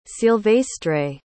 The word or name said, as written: Silvestre